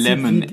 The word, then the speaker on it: Lemonaid: Ah, also, also wird ja geschrieben L-E-M-O-N-A-I-D. – E ah, also ausgesprochen im Prinzip. Lemonade. – Wie, genau, also ausgesprochen wie halt die normale Limonade, aber anders geschrieben.